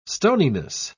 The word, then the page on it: stoniness: British English